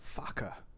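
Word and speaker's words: Farquhar: Technically, it's not even Fakwa. – It's not even Fakwa? – It's Faka. – Really? – Yeah. Is that the actual pronunciation? – Yeah, his own family pronounces it as William Faka.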